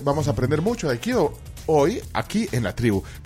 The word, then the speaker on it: Aikido: Vamos a hablar de Aikido o Aikido. ¿Cómo se dice, eh, ¿Usted eh, Aikido. – Esa es la pronunciación correcta.